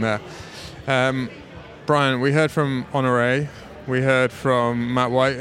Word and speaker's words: Honoré: So the correct pronunciation of his name in Danish would be Mikkel Frølich Honoré.